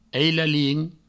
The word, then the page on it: ailalin: eilalin